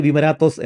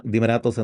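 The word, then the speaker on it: ദിമരാത്തോസ് (Δημάρατος): ഈ ഓൾഡ് കൊയിനെ ഗ്രീക്കിൽ വിമരാത്തോസ് എന്നൊക്കെയാണ് വായിക്കുന്നത്. നമ്മൾ ഇംഗ്ലീഷ് കലർന്ന ദിമരാത്തോസ് എന്ന ഉച്ചാരണം തന്നെയാണ് ഫോളോ ചെയ്യുന്നത്.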